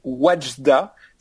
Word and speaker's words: Wadjda: Alors c'est Wajda ça s'écrit W-A-D-J-D-A.